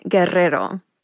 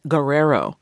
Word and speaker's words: Guerrero: Jean Guerrero will now be called Jean Guerrero. – Hear the difference? For years, the LA Times columnist accepted the anglicized Guerrero, a pronunciation that she says was forced upon her beginning in grade school.